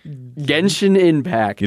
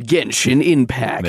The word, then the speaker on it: Genshin Impact: Fortnite and Genshin Impact. – You didn't say that right. You got a Genshin Impact. – Genshin Impact. – There we go.